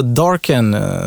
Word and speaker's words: Darken: Så Darken, som, som det ska uttalas, är egentligen världens första livsstilsmärke som inspireras av, av den digitala världen.